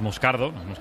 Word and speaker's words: Moscardo: Entonces, sí, como, como bien ha pronunciado Unai, es Moscardo, es brasileño,